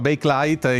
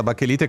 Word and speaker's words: Bakelite: Che è una di quelle cose che in realtà si pronunciava Bakelite. In italiano è diventata Bachelite, come Colgate che è diventato Colgate.